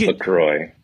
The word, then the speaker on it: LaCroix: Apparently it's LaCroix. – You'll never make it in Hollywood if you keep calling it by the French pronunciation.